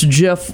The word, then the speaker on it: GIF: And they're referencing his number one interview with the New York Times where he said it's GIF with a soft G like the peanut butter brand.